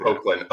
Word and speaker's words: Hoechlin: Hoechlin? Is it Hoechlin? – Yeah, I always say Hoechlin.